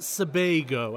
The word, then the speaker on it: Sebago: It's pronounced Sebago, as in bagel.